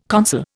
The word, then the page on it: consul: Indian